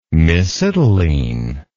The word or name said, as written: mesitylene